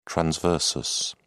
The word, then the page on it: transversus: British English